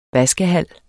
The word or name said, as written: vaskehal